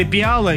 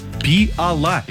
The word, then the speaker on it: пиала: Далее, вы сказали пиала, и нам всем привычно действительно произносить пиала, но правильно говорить пиала, видите ли.